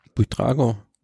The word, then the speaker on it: Buitrago: Jasper Philipsen vor Daryl MP, Caleb Youn, Punktetrikot, Juniorenfahrer, äh, Pavel Sivakov vor Santiago Botra, wie spricht man den aus? Botrago? Bu Buitrago? Buitrago.